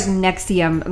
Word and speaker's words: NXIVM: So they spell it weird. It's like NXVIM. Apparently, it's pronounced like Nexium. Like Nexium? It sounds like something you take. – Isn't that for like acid reflux or something? Some pill? – Taking respiratory issues or something. I don't know. But it's spelled NXIVM.